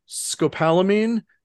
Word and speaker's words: scopolamine: Atropine, scopolamine, and ephedrine.